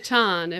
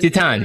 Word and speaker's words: Titane: Probably Titane if it's in Titane. – French. – Thank you. Yeah. This is why I can do the Spanish pronunciations, you do the French pronunciations, and we balance out.